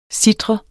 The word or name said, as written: Sitrede